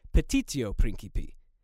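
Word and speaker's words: petitio principii: I said that Beg the Question is a translation of the Latin phrase petito principi, and that's almost correct. It's actually petitio principi. Note the missing I in my original pronunciation.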